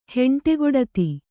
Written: ಹೆಂಡೆಗೊಡತಿ

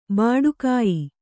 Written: ಭಟ್ಟಗೆಯಿ

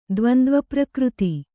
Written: ದ್ವಂದ್ವಪ್ರಕೃತಿ

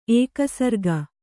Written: ಏಕಸರ್ಗ

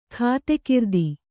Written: ಖಾತೆಕಿರ್ದಿ

